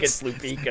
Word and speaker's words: Lupica: I remember one time because, you know, that sports writer, Mike Lupica or Lupica or whatever. Yeah, and I remember I pronounced it like that and he corrected me. Okay, so I think it's Lupica.